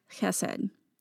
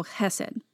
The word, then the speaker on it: chesed: It's got a sound at the beginning of the word. It, it's spelled H E S E D or C H E S E D but that sound isn't a part of our English language.